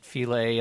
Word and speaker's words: Philae: So I pronounce it Phile. A lot of people on the mission do. I think it's pronounced differently.